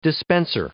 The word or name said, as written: dispenser